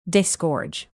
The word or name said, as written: disgorge